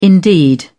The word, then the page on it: indeed: British English